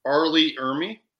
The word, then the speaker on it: Ermey: Arlie Ermey. – Ermey, that's his last name. Yes, that's right.